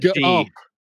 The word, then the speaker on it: Gump: Is it Forrest Jump? Are we having it's Forrest Gump.